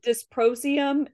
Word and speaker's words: dysprosium: I got dysprosium, neodymium.